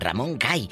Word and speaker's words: Ramón Gay: así se apellidaba, no porque era gay, y así lo pronunciaban, Ramón Gay, eh, eh, le dio raita a Evangelina Elizondo, pues no lo mató el novio.